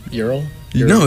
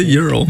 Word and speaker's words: Ural: How do you say it? I don't know. – European. – What? – Ural. – It's European. The Ural. – The Ural.